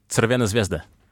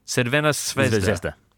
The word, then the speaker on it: Crvena Zvezda: Crvena Zvezda. – Crvena Zvezda.